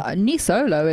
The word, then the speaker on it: Nisolo: Is it Nisolo? Nisolo? – Nisolo is how Nisolo, I said it. – Nisolo. Right. Okay.